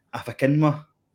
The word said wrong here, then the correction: Akinfenwa: Adi Akinfenwa.